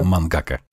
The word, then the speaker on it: мангака: Мангака, я правильно сказал? Да, да, да. Мангака.